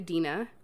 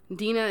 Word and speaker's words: Dina: On Instagram and Twitter, I am at The Mary Dina. Dina is spelled D-I-N-A because people actually always ask me that.